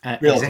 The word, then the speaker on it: realty: His experience ranges from constructing new homes to owning a reality. Yeah, I think that's right. Reality. – Realty. – Executive franchise. Realty. Ah, right. Okay. Slight different UK pronunciation then.